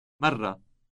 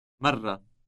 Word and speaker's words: مرة: مرة. مرة. – Note the trilled R sound. Now say first time. – أول مرة.